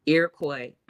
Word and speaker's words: Iroquois: Iroquois.